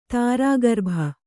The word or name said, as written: ತಾರಾಗರ್ಭ